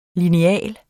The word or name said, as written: lineal